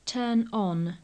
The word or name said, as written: turn on